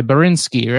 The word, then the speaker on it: Borinsky: Borinsky, how do you say that? – I cannot even read it. – B-O-R-I-N-S-K-Y, Borinsky, right? – Yeah, Borinsky.